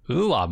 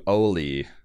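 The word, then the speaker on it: Ole: And Gator's like, "Well, he pronounces it Ula. Ula Munch." Apparently, I don't know, is the way you pronounce this if you're from where he's from. – Yeah, and apparently it's closer to Oli. This is kind of like Gator Americanizing it.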